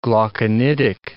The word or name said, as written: glauconitic